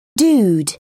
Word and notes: dude: British English

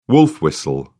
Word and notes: wolf-whistle: British English

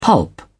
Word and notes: pulp: British English

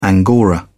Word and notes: angora: British English

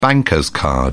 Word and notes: bank card: British English

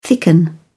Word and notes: thicken: British English

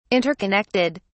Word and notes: interconnected: British English

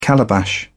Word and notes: calabash: British English

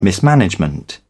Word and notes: mismanagement: British English

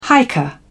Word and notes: hiker: British English

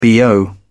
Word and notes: bo: British English

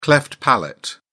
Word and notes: cleft palate: British English

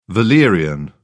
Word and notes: valerian: British English

